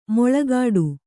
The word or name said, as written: ಮುಳ್ಳುಗಿಡ